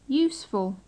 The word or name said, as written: useful